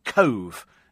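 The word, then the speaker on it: Cobh: So Cove is how you pronounce that that place in Ireland.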